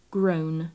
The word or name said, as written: grown